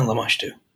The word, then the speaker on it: Lamashtu: And also on the plaque is Lamashtu. Is that how you say it? – Yeah, Lamashtu.